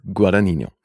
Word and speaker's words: Guadagnino: Ja, gut, aber das, wir werden ja auch nicht die ersten, die irgendeinen Namen falsch aussprechen, aber liebe ja, Jungs, der Herr heißt Guadagnino.